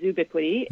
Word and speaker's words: zoobiquity: Catherine, in your previous book, you coined the word zoobiquity. What do you mean for that to describe? – So it's we pronounce it zoobiquity.